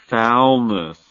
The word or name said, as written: foulness